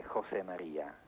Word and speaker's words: José María: Dunque, la pronuncia, diciamo, spagnola è José María.